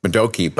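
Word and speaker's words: Modoki: Did you like my pronunciation of Madoki, by the way? – Oh, I did, yes.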